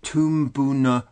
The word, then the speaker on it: Toomboona: But the title of Ryan's presentation is going to be Toomboona Stories. Ryan, am I pronouncing it correctly? – Yes, indeed.